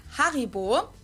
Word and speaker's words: Haribo: We pronounce it Haribo. Haribo.